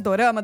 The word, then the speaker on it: dorama: Na verdade, o dorama ele é a pronúncia japonesa de drama.